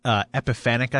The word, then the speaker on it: epiphanic: I actually pronounce it right? – It's technically epiphanic, I think.